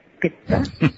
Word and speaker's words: pitta: And the 3 themes, as many of our audience knows, are called vata, pitta, and kapha, which some people pronounce vata, pitta, kapha, but it's vata, pitta.